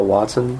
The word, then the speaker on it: Watson: Yeah, it's Brandon Gale Watson.